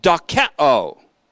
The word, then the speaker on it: dokeo: The kind of thinking that's being warned against in Hebrews 4:1 is called dokeo. D-O-K-E-O. D-O-K-E-O. Omicron O, then Omega O. Long O. Dokeo.